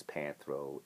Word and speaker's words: Panthro: And, you know, I should have said this in the beginning, but everybody knows it's Panthro. It's a play on the word panther, like the big black, wild feline. So Panthro Fitness, I'm like, okay, talking about ripping off Panthro, definitely ripping off Panthro, okay?